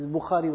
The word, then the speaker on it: Bukhari: but he can't pronounce Bukhari properly himself, say Bukhari Sharif. It's Bukhari with a kha.